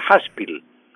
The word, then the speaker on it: Haspil: El apellido se escribe Haspil, pero se pronuncia Haspil.